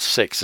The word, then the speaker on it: CEX: In Sydney there's a franchise called Sex. It's spelled CEX and it stands for Computer Exchange.